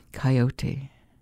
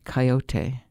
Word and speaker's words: coyote: I like lying in bed as I did last night with moonlight streaming in the window, listening to their song, Coyote. That name comes from the Aztecs' word for them, coyotl, and should, to my way of thinking, always be pronounced coyote, not coyote the way it sometimes is.